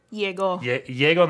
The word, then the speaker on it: Llegó: So, I'm going to play It's Christmas Time in Texas from the new Los Lobos record called, is that Lego? How do you say that? Llegó Navidad.